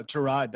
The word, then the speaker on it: Tyrod: No, Tyrod. That's how he pronounces his Tyrod.